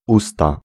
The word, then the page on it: bouche: /buʃ/